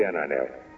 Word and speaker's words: Ananel: Ananel, A-N-A-N-E-L. Ananel, Ananel, ou Ananel, como dizemos nós. Que muita gente quer que o L seja pronunciado à moda castelhana. Brasil, nacional, mas só o gaúcho consegue dizer isso.